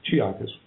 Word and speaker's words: Chiakas: Chiakas.